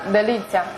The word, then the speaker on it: Delizia: 它叫 Delizia，它是意大利文的发音，跟英文的美味那个 delicious 有点像，代表了我们最初对这个品牌的一个期待。